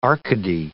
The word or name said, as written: Arcady